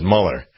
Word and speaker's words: Mueller: I think it's pronounced Mueller, but every once in a while I'd say Mueller because his name is spelled M-U-E-L-L-E-R. And Mueller would not normally have an E with it.